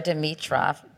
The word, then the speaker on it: Dimitrov: So I know you dated a number of professional athletes, like Grigor Dimitrov.